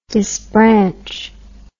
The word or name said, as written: disbranch